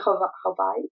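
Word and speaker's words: Hawaii (Hawaiʻi): So but I noticed you said Hawaii. – Yeah. So that's all you're supposed to pronounce it. Just like, you know, like most, you know, like even with Spanish or Indian, everybody has like the inflections and diacritics. So there's it's called diacriticals.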